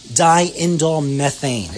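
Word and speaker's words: diindolylmethane: Now, some additional things for reducing the aging process would include a chemical that's found in cruciferous vegetables, and it's abbreviated DIM, D-I-M, or dionylmethyl, I'm sorry, I always mispronounce this, even myself after all these years, is diindolmethane. I got to say it slow, not three times fast.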